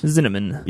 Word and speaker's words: Zinnemann: This from the 1952 film High Noon, directed by Fred Zinnemann. Zinnemann. – Yep. – Rhymes with cinnamon.